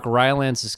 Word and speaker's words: Rylance: But then Mark Rylance's character, hope I'm saying his name right.